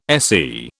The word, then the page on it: SE: British English